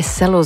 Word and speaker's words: cello: Maar daarom mocht je je vragen stellen waarom wij cello zeggen. Omdat het zo moet. Dat is eigenlijk de enige correcte uitspraak.